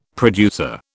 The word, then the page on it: producer: British English